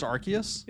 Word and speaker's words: Arceus: I think also, I think it's pronounced Arceus. – Is it Arceus? – It's Arceus, yeah. Which seems wrong. – Seems wrong. – But it's Arceus.